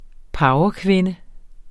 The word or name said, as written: powerkvinde